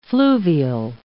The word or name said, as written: fluvial